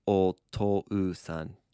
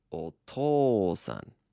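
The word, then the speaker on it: O to san (お父さん): Now, when you say this word, you don't really say O to U san, do you? – No, not really. – That's because an U after an O makes a long O sound. – Oh. – Yeah, like that. So if I wanted to properly pronounce the word for dad or father, it would be O to san.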